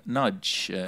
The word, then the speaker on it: Nagy: His name is Peter Nagy. – It's spelled P-E-T-E-R-N-A-G-Y.